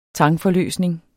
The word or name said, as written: tangforløsning